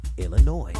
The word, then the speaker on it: Illinois: It ends with an S, but don't say it, boy, when mentioning Springfield, Illinois.